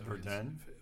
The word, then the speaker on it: Verden: V-E-R-D-E-N. – So Verden? – And in German, you pronounce the V like an F in English.